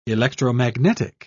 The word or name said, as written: electromagnetic